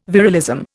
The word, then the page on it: virilism: Indian